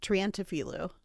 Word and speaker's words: Triantafilou: I was like, how do you say it? – Triantafilou. – Okay, got it.